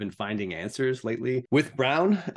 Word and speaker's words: Braun: I wanted to actually talk a little bit about Christian Brown, pronounced Brown, apparently not Braun. I learned that for the first time this week.